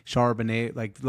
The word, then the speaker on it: Charbonnet: You already got Chardonnay. Is that how you pronounce it? – Charbonnet, like the wine, but like with a B.